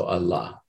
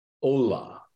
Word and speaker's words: Allah: Now, in, almost everybody who works on the Sufis would spell that A-L-L, long A-H, okay? And so Allah. But the problem is in Bengali, you can't produce that sound. If I were to transliterate the Bengali, it would be pronounced Ola.